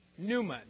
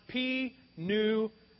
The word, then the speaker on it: pneuma: And the word for Spirit is the word pneuma, and it's pronounced pneuma, not p-n-u-m-a, okay? That's not how you do it. You don't pronounce the P, you just pronounce pneuma, okay?